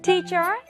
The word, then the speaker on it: teacher: Teacher sounds like richer. Teacher sounds like future. Teacher sounds like nature. Richer, future, nature, and teacher. Teacher, 선생님. And the next one, M-O-M, mom.